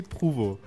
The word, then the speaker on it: Prouvost: Pues me llamo Louis Pruvo, pero no se escribe así.